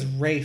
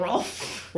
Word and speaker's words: Ralph: His name is Ray Fiennes, and Ray is spelled Ralph. So. – I've been saying his name wrong. – Have you been saying Ralph Fiennes? – I've been saying Rolf.